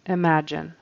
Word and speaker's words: imagine: But he kind of leaves that out altogether and says, imagine. You know, we got to have that J sound in there.